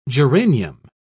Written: geranium

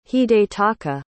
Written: hidetaka

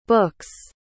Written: books

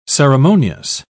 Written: ceremonious

